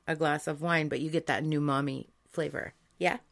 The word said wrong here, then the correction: umami: Well, it's umami, not new mommy.